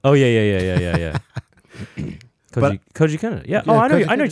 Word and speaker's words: Koji Kondo: Kondo, I believe the pronunciation is Koji. Kendall. – No, it's Koji Kondo. – Oh, yeah. Koji Kondo? – That's Koji Kondo.